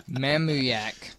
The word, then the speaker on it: Mamuyac: Mamuyak. – Mamuyak? – Mamuyak. – Mamuyak. M-A-M-U-Y-A-C.